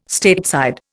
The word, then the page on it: Stateside: Indian